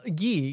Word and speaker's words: Guy: Anyway, this guy Gui or Guy said, "Oh, Gui, Gui, because in French it would be Gui."